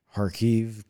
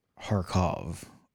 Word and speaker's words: Kharkiv: That's why you'll see like Kharkiv, K I believe is silent. In Russian, that's Kharkov, O-V. Well, in Ukrainian, they spell it I-V, so it's Kharkiv.